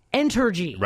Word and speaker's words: Entergy: Entergy. – Entergy. – Right, right, right. – Sounds like energy, but it's Entergy.